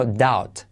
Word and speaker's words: doubt: No se dice doubt, sino doubt. Doubt. Ese au es au y la B no se pronuncia y la T.